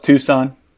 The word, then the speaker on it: Tucson: yeah, no, it's Tucson. The C is silent.